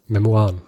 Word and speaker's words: Memoiren: Ja, der hatte das Ziel, später Memoiren, schreibt man, sagt man Memoiren? – Memoiren.